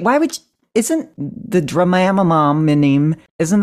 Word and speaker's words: Dramamine: No pronouncing that drug with a long A on the first syllable. – Instead of Dramamine? – Yeah.